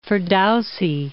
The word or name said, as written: Ferdowsa